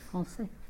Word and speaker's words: Français: Français.